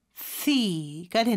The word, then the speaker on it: healthy: 헬 씨가 아니죠. TH 발음해 주셔야 되는데 여기서는 무성음이에요. 그래서 스처럼 발음하는 것 같은데 발음을 내뿜는 소리로 해주시고요. 그래서 healthy가 되는 거죠.